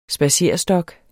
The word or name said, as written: spadserestok